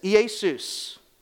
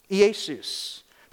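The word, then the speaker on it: Iesus (Ἰησοῦς): But also, in the Greek, they take Yeshua from Aramaic, and it's Iesus. Iesus. That's how a Greek person would pronounce it, because they can't pronounce all these other words, so they would make it Iesus.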